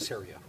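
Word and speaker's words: Ceria: Is that how you pronounce it? – Syria. Rhymes with area.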